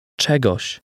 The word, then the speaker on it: Czegoś: Czegoś.